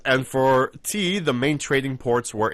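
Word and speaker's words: tea (茶): The exact same character, the Chinese character that he mentioned earlier, is pronounced tea.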